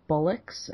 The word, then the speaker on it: bollocks: Because in America, obviously, most people would say bollocks, and it's like it's not a bull. It's a ball.